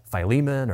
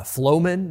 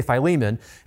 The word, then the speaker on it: Philemon: But what's great about this little book is that if you were to ask like three different people how you pronounce it, I think you'd get five different answers. It's like Philemon, or maybe Philemon, or maybe Philemon, or I heard someone say Flowman this last week. I mean, there's all kinds of ways, I guess you could say it. We're going to say Philemon.